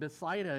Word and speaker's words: Bethsaida: Pronouncing it Bethsaida is probably the correct pronunciation for that, and I appreciated the reading by Brother Linkus.